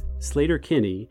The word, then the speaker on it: Sleater-Kinney: By the way, locals pronounce the name of the road Sleater-Kinney, not Sleater-Kinney, and that's how the band has continued to say the name.